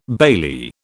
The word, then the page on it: bailee: British English